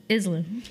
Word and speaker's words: Island: the S is silent. Island.